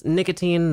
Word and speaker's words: Nikatine: Enovan is spelled E-N-E-V-U-N. – Awesome. My name, of course, is Nicotine. That's N-I-K-A-T-I-N-E.